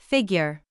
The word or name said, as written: figure